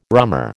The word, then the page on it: rummer: American English